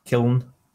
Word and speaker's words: Kiln: I mean, there have been rumors in December that maybe, yeah, exactly, that Double Fine might launch their small passion project, Kilan or what? – Kiln, yeah. Is that how you pronounce it? – Yeah. – Kilon? – Kiln. K-I-L-N. Kiln.